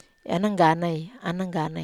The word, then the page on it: anangganai: Wamesa